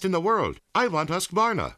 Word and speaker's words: Husqvarna: So I teach you now how to say Husqvarna. You say husk like corn and varn like varnish, without the ish, and nah.